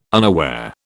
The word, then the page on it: unaware: British English